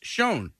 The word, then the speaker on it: Chão: It says pronounced Chão.